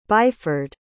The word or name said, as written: byford